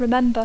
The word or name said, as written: remember